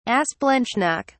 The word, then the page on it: asplanchnic: British English